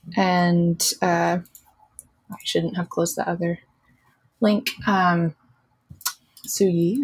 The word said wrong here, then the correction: Siyu: I think is her name. – Is the other I can spell it. – Siyu? – Yeah, Siyu. – Siyu. Thank you.